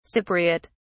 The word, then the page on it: cypriote: British English